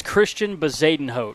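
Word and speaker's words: Christian Bezuidenhout: This is Christian Bezadenhout.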